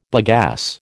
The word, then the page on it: bagasse: American English